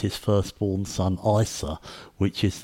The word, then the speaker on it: Isa: They pronounce it Isa.